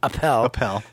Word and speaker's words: Appel: Because Bruce Springsteen needed a producer, so he goes, and this guy, Mike Appel. Said, it rhymes with lapel.